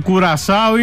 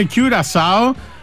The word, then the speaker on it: Κιουρασάο: Και θα επιμείνω και σε σένα, δεν υπάρχει Κουρασάο, είναι Κιουρασάο.